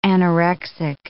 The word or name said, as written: anorexic